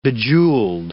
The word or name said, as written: bejewelled